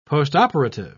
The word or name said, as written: postoperative